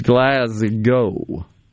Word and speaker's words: Glasgow: It's Glasgow.